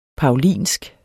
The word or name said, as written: paulinsk